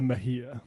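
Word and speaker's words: Mejía: Yesterday, we had announced that Mieja in New Jersey's 11th district had won the special election. – That's Ana Lilia Mejia. – Mejia. – Is the one person who can pronounce it in this office.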